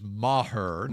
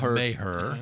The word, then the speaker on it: Maher: And the other guy, Brett Maher, and that is how you pronounce it according to the media guide. It is Maher. Not Maher.